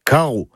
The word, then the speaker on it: karv: Carreau. K-A-R-V. Oui, alors le V, enfin, se prononce O. C'est comme ça, faut l'apprendre.